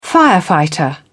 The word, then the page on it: firefighter: British English